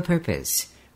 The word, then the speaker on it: purpose: 왜냐하면 명사이기 때문에 강세가 이름절에 있습니다. 이름절에 있을 때는 per라고 읽고 뒤에 있는 걸 pus, purpose 이렇게 읽어야 됩니다.